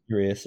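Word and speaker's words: Urías: Urias? – Urias, yep.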